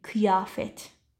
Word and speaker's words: kıyafet: Kıyafet نداریم. Kıyafet. دقت کن به تلفظت.